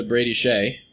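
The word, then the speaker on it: Brady Skjei: We're going to go from Kevin Hayes to Brady Shea.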